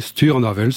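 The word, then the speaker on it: Stur an Avel: Stour Anavel, est-ce que j'ai bien prononcé Chaque fois, je vais vous demander la chose. – Oui, Stour Anavel, c'est bien ainsi.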